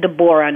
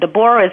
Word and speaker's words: Debora: Now, if you you follow me on on Twitter, I'm at Debora, and Debora is spelled DEBORA, okay.